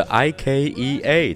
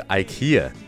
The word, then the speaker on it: IKEA: 那很多人会把宜家的英文读成 IKEA，甚至有些人还会读成 IKEA。那很明显，以上都是错的。它在英文中真正的读音应该是 IKEA，IKEA。那前面的字母 I 其实就是跟 iPhone 的 I 是一样的发音的。